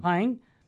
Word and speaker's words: Hying: You said, I affirm and support a statement by my brother bishop, His Excellency Donald Hying how do I say that name? Is it Hying? – Hying. On March 21st, 2023, the entire statement can be read on the Diocese of Madison.